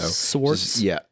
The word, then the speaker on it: Swartz: Close enough. Swartz. – Say it again. – No S-C-H. It's S-W-A-R-T-Z. So Swartz? – Swartz.